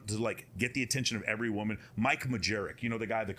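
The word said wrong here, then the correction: Majlak: How'd you say it? – Malak. – Sorry, I said his name wrong.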